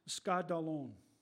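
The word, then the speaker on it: skandalon (σκάνδαλον): And to Greek, in the Greek word, The Greek word is pronounced skandalon, S-K-A-N-D-A-L-O-N, from which the word scandal derives.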